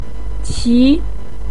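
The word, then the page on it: qi2: tone 2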